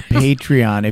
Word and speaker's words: Patreon: Yes, and thank you for saying it right, because apparently I said it wrong last time. Yeah, Patreon.